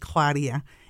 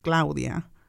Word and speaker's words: Claudia: And even saying my name in Spanish, saying Mexico in Spanish, when I introduce myself, I used to be like Claudia, and now it's Claudia. That's my name, and that's how you pronounce my name.